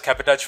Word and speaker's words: cabotage: Okay, tell us actually what is cabotage. – Okay, so cabotage. – Did I say it right? Cabotage? – Cabotage. Cabotage. Okay.